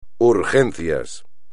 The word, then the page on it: urgencias: espagnol